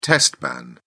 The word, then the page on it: test ban: British English